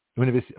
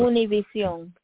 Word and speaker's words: Univision: Univision. – Mm-hmm. Thank you for the pronunciation. Thank you. Okay, so it's El Salvador. – Univision.